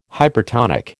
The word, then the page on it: hypertonic: American English